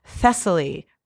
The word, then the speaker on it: Thessaly: So, you know, I tried to find something and I found some odd lute music, and I have a wedding processional from Thessaly. And I don't know if I'm saying, You're saying it right.